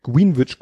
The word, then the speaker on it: Greenwich: Dann äh, haben wir in irgendeinem Kontext habe ich wahrscheinlich wieder Greenwich, Greenwich ausgesprochen, was natürlich Blödsinn ist.